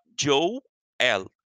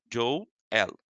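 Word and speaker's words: Joey L: E o meu último fotógrafo se chama Joe L. O Joe se escreve J-O-E-Y, Joe L.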